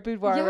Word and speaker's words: boudoir: I said the word boudoir right. – Yeah, you actually pronounced it right.